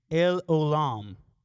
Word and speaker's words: El Olam: And it is El Olam. That's how you say it in the Hebrew.